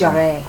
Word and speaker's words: Jarre: Maurice Jarre, I think, is how you pronounce his name. Really great composer of huge score. – Want to be fancy? It's Jarre. – Jarre.